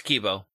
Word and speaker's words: Kibo: It is the Kaibo Pikachu. Or Kibo? I think I was saying it wrong. I think somebody said it's Kibo because the I is the E. – It's Kibo.